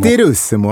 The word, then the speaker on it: Turismo: Jag gillar att du hade italienskt uttal på Gran Turismo. – Turismo, ja.